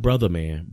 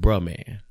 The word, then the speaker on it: Bruh Man: Not Brother Man, but Bruh Man. You know, you got to say it like that.